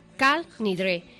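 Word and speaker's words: Kol Nidre: Bueno, pues comienza con una oración que para los sefardíes se pronuncia Kal Nidre.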